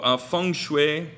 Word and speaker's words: feng shui: 그 중국식 와. 발음으로 하는 거예요. 영어에서 펑쉬. 그래서 예 그렇게 중국 발음 정말 그렇게 합니까? – 네, 모르겠어요. 아, 이건 미국식 중국 발음. 아, 진짜 펑쉬. 중국 발음 아니잖아요.